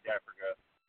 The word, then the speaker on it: Dafrico: Well, the Facebook would just be under my name, Ray Dafrico, D-A-F-R-I-C-O, is how you spell it.